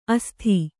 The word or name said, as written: ಅಸ್ಥಿ